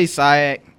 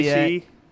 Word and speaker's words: SIAC: Is it the SEAC? – Say SIAC. That's me. You can say SEAC. Yeah, I say SIAC. I don't know why I say it like that, but I know one thing.